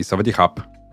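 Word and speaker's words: савадихап (สวัสดีครับ): Например, там, на тайском отдельно говорят женщинам привет и мужчинам по- по-разному. – Интересно. – Да, савадиха и савадихап.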